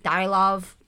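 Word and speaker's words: Dyatlov: Don't think they pronounced their T's, though. It's like Dialov, because it's actually Dialov Pass, and now I can't.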